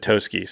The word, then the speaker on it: Toski: She pronounces it Toski, so I think so.